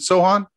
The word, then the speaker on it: Sochan: San Antonio Spurs, Jeremy Sochan. Uh-huh. I don't know how to say his last name. Is it Sochan? – It's Sochan.